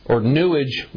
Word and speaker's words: Newage: And I'm going to read you an article now from actually a Newage website, because it rhymes with sewage, Newage website concerning what the pineal gland is good for, why this activation of the pineal gland is so necessary.